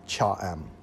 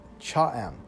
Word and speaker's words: Cha-am: This beach city is called Cha Em. It's spelled C-H-A-A-M, Cha Em.